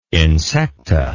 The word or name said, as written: insecta